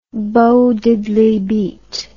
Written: Bo Diddley beat

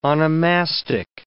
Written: onomastic

